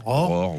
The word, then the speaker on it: ROR: Alors le fameux vaccin, on prononce comment ? ROR ? – ROR, oui.